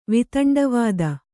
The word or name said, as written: ವೈತಂಡವಾದ